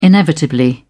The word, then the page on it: inevitably: British English